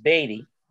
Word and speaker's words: Baty: B-A-T-Y, pronounced Beatty.